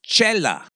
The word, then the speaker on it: Xela: Still, we end up with X-E-L-A, pronounced Chela, with not a C or an H to be found.